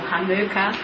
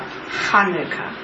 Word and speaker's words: Hanukkah: As Rosemary said, Hanukkah can be Hanukkah or Hanukkah or Chanukkah, as Anglicised say, but that's supposed to be pronounced, which I can't really do, with a very guttural H at the beginning of Hanukkah. – Hanukkah. – Hanukkah. Very, very strong guttural sound, which if you're in Israel, you hear lots of Sabra talking in that way.